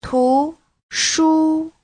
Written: tu2shu1

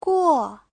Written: guo4

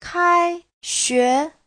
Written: kai1xue2

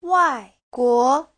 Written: wai4guo2

